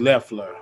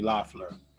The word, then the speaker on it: Loeffler: It's Leffler. I was saying it's Kelly Leffler, not Kelly Loeffler.